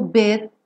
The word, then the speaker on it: bit: So, if I stay here just a little bit longer. Bit, você pode ou não pronunciar esse T só com aquele sonzinho da percussão.